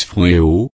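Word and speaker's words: phronéo (φρονέω): Le mot grec pour penser ou sentiment ici, c'est phronéo, on le prononce phronéo,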